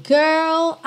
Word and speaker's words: girl: Now, girl, I know the difference. Esse girl também tem a pronúncia um pouquinho diferente no inglês britânico. No americano, a gente enrola mesmo R, girl. No britânico, girl, girl, ok?